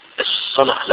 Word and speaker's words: الصلاة: الصلاة لما تقراها في المصحف تلاقيها صاد لام واو ت مربوطة، مش كده؟ مش لام ألف، صاد لام واو ت مربوطة، وتلاقي فوق الواو مادة كده عشان يقول لك انطقها ألف، لأن الصلاة أصلها صلوة الواو في أصلها أصل الكلمة صلوة، والصلوة واحدة الصلة.